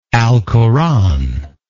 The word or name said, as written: alcoranic